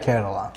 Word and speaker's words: Kerala: Of Kerala. Is that how you say it? – Yeah, Kerala.